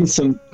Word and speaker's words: Wilson: Wilson, como la pelota del náufrago.